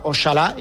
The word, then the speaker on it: Oxalá: Es un origen árabe, lógicamente, que luego se adaptó al español antiguo y en el español antiguo, como recordamos, existía la X y entonces era Oxalá, que se pronunciaba Oxalá, y luego en el español actual pasó a Ojalá.